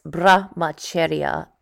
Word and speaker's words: Brahmacharya: It's actually pronounced Brahmacharya, and it's a Sanskrit word meaning chastity.